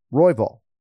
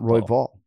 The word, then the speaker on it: Royval: But no, you got to emphasize the first syllable here, you know. Royval. Not Royval.